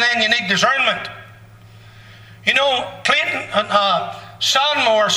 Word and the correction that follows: Seymour: Seymour, I didn't say it right, Seymour,